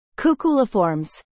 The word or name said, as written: Cuculiformes